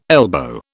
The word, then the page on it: elbow: British English